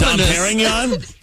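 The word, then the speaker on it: Dom Pérignon: Wait, what's it called again? I'm sorry, I've always been pronouncing it incorrectly, apparently. What is it? – Dom Pernin. Pernoning. – Anonymous. Dom Perignon? – Yes.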